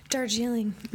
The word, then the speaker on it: Darjeeling: Darjeeling.